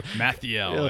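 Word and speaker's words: Malthael: I really loved Bales in 2, and I really liked Malthus, whatever it's called. – Mathiel, I think is how you pronounce it.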